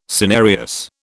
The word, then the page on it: cinereous: British English